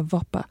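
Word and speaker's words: bhopa: It's, it's B H O P A. I say a voppa, and they are usually goat herders and they're illiterate.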